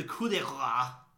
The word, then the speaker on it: coup de grace: Time for the coup de grace.